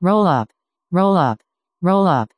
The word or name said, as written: rollup